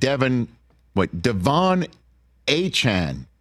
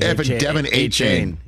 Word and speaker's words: Achane: And then you see Tyreek Hill and Devon Achane is apparently how he wants his name to be pronounced. – Devon. – We were saying Devon H. – A-Chain. – Devon A-Chain.